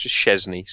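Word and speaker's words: Szczęsny: Even since we have a goal, we have a Polish goalkeeper who has the same arrangement of Z's and S's, and it's pronounced Szczesny.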